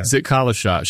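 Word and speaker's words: Zitkala-Sa: Then, like almost immediately after, I got to this part in your book, a woman named Zitkalasa, is that how you say it? – Zitkalasa.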